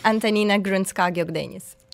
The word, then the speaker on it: Antonina Grunska-Gokdeniz: Please Hello, say it again if I said that wrong. – It's almost the right version. It's, but not quite. – I'm very impressed, Annabelle. – Thank you. It's Antonina Grunska-Gokdeniz.